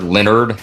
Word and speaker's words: Leonhard: You know who's not on my ass list, and it goes to the AFC East, is Leonhard Leonard. How do you say his name? He's one of my favorite coaches. Jim Leonard. – Dude, stop. – He's spelled like Leonhard.